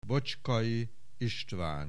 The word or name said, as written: Bocskai Istvan